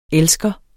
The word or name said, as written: elsker